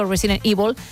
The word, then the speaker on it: Resident Evil: Creo que se están riendo los compañeros de que digo Resident Evil. Me da igual, es cómo se pronuncia. Es verdad que aquí hemos dicho siempre Resident Evil.